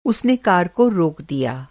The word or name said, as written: रोकना